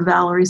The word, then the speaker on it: Valorie: Just remember Valorie spelled like Valor, V A L O R I E.